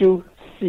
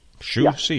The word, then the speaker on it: Xu Xi: No, I got Xu Xi, is that correct? – Xu Xi, yeah. – Xi. I came close. Xu Xi. – Yeah. You're close. But the Chinese names are very difficult.